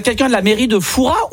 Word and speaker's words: Fouras: Eh bien écoutez, on dit Fouras. Ah ben, on ne prononce pas le S. – Et donc on dit bien Père Fouras.